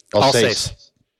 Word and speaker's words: Alsace: How do you say that? – Alsace.